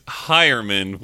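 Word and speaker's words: Heuerman: It's Hyerman, which you'll probably forget again because it's not how it looks, so good luck. – Yeah, Jeff Hyerman.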